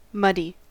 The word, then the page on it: muddy: American English